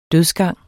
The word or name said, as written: dødsgang